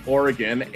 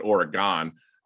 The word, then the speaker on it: Oregon: Like, I'm from the Midwest, and we were told we had to pronounce it Oregon, uh, but it's actually Oregon. So I like to say it the right way.